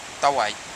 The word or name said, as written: tawai